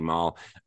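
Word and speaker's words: Marle: Is it Marl? Is that how you pronounce it? – Yeah, that's how I pronounce it. – Yeah, that's how, there's different ways because it's that E which confuses people. On the end of a name, but I say Marl, and you basically go see your friend who's absolutely useless in playing with her.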